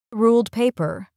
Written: ruled paper

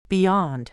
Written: beyond

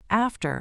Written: after

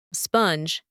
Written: sponge

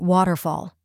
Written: waterfall

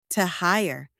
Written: to hire

